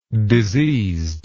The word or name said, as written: diseasedness